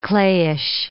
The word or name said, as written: clayish